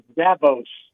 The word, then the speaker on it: Dabo's: And we're going to rely on our good friend Andy Burcham to settle the question, is it pronounced like Dabo Swinney or is it Dabo's Park now, Andy? – It is Dabo's.